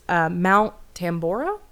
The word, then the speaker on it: Tambora: Probably going to mispronounce this. Mount Tambora? – Sure. – Is what it looks like. Sounds on paper.